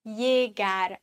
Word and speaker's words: llegar: Doppel-L wird im Spanischen wie ein J ausgesprochen. Llegar. Außer in Argentinien, da würde man sagen llegar.